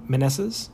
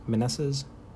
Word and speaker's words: Meneses: On Chris's end, 8 ribbies from Dansby, 4 runs, 3 homers, 7 ribbies from Jose Ramirez, and a .389 average from Meneses, I believe is how I heard it pronounced on the broadcast last time I watched a Nationals game. Meneses.